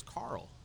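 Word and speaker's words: Karl: My middle name is Carl, actually spelled with a K.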